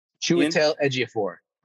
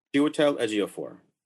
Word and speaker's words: Chiwetel Ejiofor: How do you say it? – Chiwetel Ejiofor. – Chiwetel Ejiofor. Right? Okay, all right.